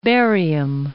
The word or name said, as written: Barium